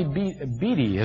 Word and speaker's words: Beattie: There's a gal named Melody Beattie, is the correct pronunciation of her last name.